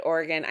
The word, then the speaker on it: Oregon: And yes, I said Oregon. I said it right.